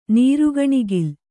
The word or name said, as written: ನೀರುಗಣಿಗಲು